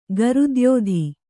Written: ಗರುದ್ಯೋಧಿ

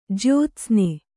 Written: ಜ್ಯೋತ್ಸ್ನಿ

